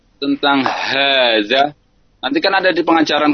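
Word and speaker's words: Haza (هذا): Ya biasa aja, ha. Haza. Penekanan dari sisi ha tebal ya. – Ha tebal. – Ha tebalnya itu. Terkadang karena kurang ini hazanya tipis, haza gitu, enggak haza.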